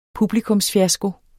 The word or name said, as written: publikumsfiasko